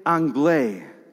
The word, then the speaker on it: Anglais: A visiting general exclaims he has only ever tasted food like this at the famous Café Anglais in Paris. I know, you don't pronounce the S.